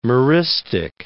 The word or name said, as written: meristic